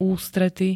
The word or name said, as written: ústrety